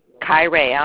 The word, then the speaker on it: Kiraya: It's Kyrea. I'll spell it. K-I-R-A-Y-A. – Mm-hmm. – Keston. K-E-S-T-I-N.